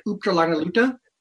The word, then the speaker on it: Uptalanaluta: There's actually a word in the Yupik language, which is pronounced roughly something like Uptalanaluta. That literally translates as always getting ready. – Uptalanaluta.